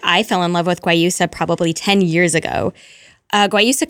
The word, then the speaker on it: guayusa: And you wanted, and I hope I'm saying it right, guayusa? Did I say that right? – Guayusa. – You wanted guayusa.